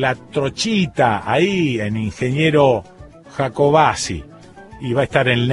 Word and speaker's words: Jacobacci: Tengo siempre la pronunciación Jacobacci, pero bueno, qué sé yo, no sé. Ellos, los de ahí, que son los que más derecho tienen, dicen Jacobacci. Y punto.